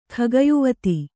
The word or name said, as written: ಖಗಯುವತಿ